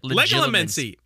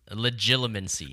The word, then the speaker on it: Legilimency: Eye contact is often essential to legalomancy. – God damn it. – I said it right that time! Did I not? – Legilimency. – Legilimency.